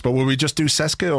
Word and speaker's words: CSKA: It's pronounced CSKA. – CSKA. – But there's CSKA, I think. Like a T in it.